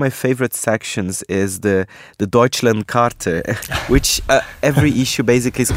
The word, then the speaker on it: Deutschlandkarte: Well, I like the way you pronounce Deutschlandkarte. That's nice. It's perfect. – The Brazilian accent. – Perfect. Yeah, perfect.